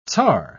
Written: tzar

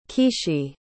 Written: Kishi

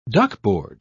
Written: duckboard

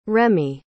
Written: remi

